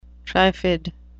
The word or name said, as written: triffid